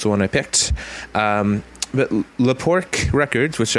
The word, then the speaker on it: Le Pork: And The Luftouts are from the net label Le Pork Records. And that's, or is it Le Pork? And that's how it's spelled, L-E-P-O-R-K.